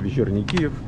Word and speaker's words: Вечерний Киев: Черный или черний? – Вечерний. – Вечерний. – Да. – Киев. Киев. Вечерний. Все, друзья, мы разобрались с произношением.